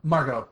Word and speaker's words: Margot: How would you pronounce her name? Margot or Margot? – Margot. Margot Kidder. – Yeah, I figured that too. It was silent.